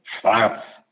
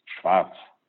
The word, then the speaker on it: Schwarz: And the second name is Schwarz, S. Schwarz, means black in English, and no Schwarz, and Schwarz, and no T inside, it's Schwarz.